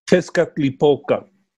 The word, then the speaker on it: Tezcatlipoca: Tezcatlipoca. – That's why I can't say it. – That's right. – So, say it again. – Tezcatlipoca.